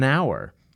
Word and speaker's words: hour: like an hour. The word hour is spelled with an H, but the H is not pronounced, so it's an hour.